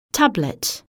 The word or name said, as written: tablet